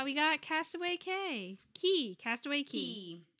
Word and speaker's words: Castaway Cay: I'm like, I understand that it's pronounced Key, but like, Castaway K sounds way better than Castaway Key.